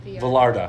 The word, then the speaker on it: Vallarta: Puerto Vallarta. – It's a double L. – Which part? Vallarta. Mm-hmm. Puerto Vallarta. – Yeah, so you have to pronounce it as a Y.